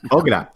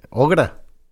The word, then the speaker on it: Augra: Ogra. – Oprah. – Pues, es que se escribe Augra. – Ogra.